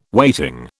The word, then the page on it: weighting: British English